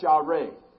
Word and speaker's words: yare: The word for fear in the Hebrew is a word that we pronounce yare, Y-A-R-E.